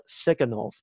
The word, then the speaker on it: Tsygankov: and his name is Viktor Siganov. And it's spelled T-S-Y-G-A-N-K-O-V.